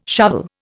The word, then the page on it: shovel: Indian